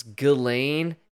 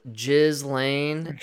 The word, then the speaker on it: Ghislaine: Can we all stop with this Ghislaine? It's spelled Ghislaine.